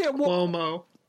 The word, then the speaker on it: Cuomo: It's pronounced Cuomo.